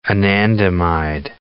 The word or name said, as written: anandamide